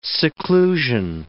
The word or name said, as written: seclusion